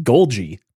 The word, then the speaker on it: Golgi: It's the Golgi apparatus. – What? – It's pronounced Golgi.